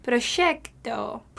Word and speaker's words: proyecto: Well, in Spanish, it's spelled P-R-O-Y-E-C-T-O. Notice that this is the letter Y that can be pronounced two different ways. – Which is why I mentioned the spelling. – I tend to pronounce it like the S-H sound of she. Proyecto.